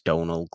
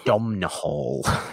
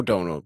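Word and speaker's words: Domhnall: About time, where we'll all pronounce Donald Gleeson correctly. – Yes. – Domnall. as it's correctly pronounced. No, it isn't, obviously. – No. Only say it like that for spelling purposes.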